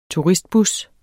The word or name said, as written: turistbus